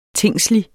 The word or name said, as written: tingslig